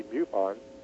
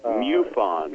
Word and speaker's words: MUFON: pronounce MUFON without it sounding like you're imitating a cow? Are you saying it correctly? – I'm saying it like I was told that it should be MUFON. – MUFON.